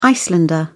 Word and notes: Icelander: British English

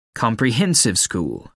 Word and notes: comprehensive school: American English

